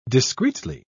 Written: discreetly

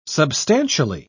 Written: substantially

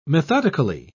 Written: methodically

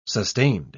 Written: sustained

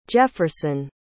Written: jefferson

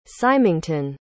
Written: Symington

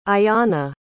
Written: ayanna

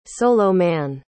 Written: soloman

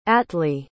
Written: attlee

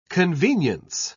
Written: convenience

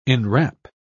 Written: enwrap

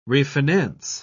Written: refinance

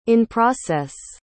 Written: in-process

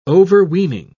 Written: overweening

